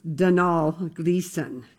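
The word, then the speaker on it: Domhnall Gleeson: Dunhall was like, it's pronounced Dunhall Gleason.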